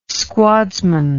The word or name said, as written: squadsman